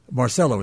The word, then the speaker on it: Marcello: Marcello is the way he pronounced it.